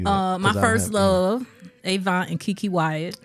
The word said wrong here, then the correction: Avant: It ain't Avon. – Nice Avant. – What is it? – Avant. You too smart for that.